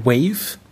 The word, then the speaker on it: WAV: Eine WAV-Datei äh, oder richtig ausgesprochen WAVE Datei, mhm.